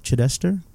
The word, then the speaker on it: Chidester: Brian Chedester. Chidester? – That's right.